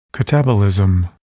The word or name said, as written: catabolism